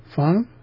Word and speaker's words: feng: Feng should be feng, not feng. That's an incorrect pronunciation being given there by the Google. – Feng. – Feng. It should be simply rising, not down and up.